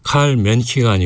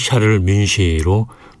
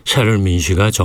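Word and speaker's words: Charles Munch: 그래서 칼 맨시가 아니고 샤를 민시로 발음을 해야 되죠. 지금도 고집하는 분들이 계신데 샤를 민시가 정확합니다.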